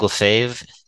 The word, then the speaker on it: Lefebvre: Craig Lefebvre is my name. The last name is spelled L-E-F-E.